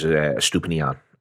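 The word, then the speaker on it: Estupiñán: But when Brighton do it, they use Stupinian. Have I pronounced that right? You're good at pronunciations. – It was decent.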